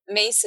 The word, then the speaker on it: Myss: I had read at the time I was going through lots of crises, some books by Carolyn Mace. And Mace is spelled M-Y-S-S.